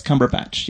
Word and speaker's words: Cumberbatch: Is that, is it Benedict Cumberbatch? Am I saying that right? – I believe so, yes. Cumberbatch, yes. I had no idea because you guys didn't tell me because you guys went to dinner without me again, so.